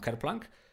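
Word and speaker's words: Kerplunk: właśnie tą w Lookout wydaną Kerplank, czy różnie to ludzie wymawiają, ale ja to tak wymawiam, e, no to to jest, no to jest e, dwa kompletnie różne światy.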